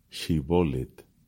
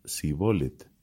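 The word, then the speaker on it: Shibboleth: Si él respondía que no, le obligaban a pronunciar la palabra Shibolet. Pero él decía Sibolet, porque no podía pronunciar correctamente.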